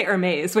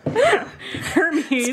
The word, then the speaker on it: Hermes: But then her sister, who is also harpy, who has become romantically involved with Hermes, or I always want to say Hermes, which I know is a handbag company and not the name of a god. Hermes.